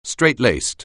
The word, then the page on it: straitlaced: anglais